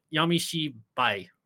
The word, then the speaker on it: Yamishibai: But Yamishibi Japanese Ghost Stories. – You almost said it. Yamishibai.